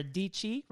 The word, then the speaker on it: Dietschy: We um, have already recorded podcasts with the Dashleys, who we just went on a trip to Jackson Hole with, and Sara Dietschy, rhymes with Peachy.